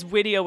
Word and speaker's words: Widio: You might want to say Widio with a W. – Widio, yes. It is Widio with a W.